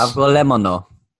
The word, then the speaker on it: Avgolemono: Hope that's Avgolemono. Go ahead. – I couldn't do that. – Avgolemono.